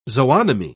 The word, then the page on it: zoonomy: British English